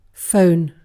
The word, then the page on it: phone: British English